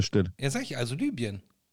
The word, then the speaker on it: Libyen: am besten spricht man Orte mit Y einfach so aus, wie sie im Griechischen auch heißen, nämlich I. – Ja, Libya. – Libyen.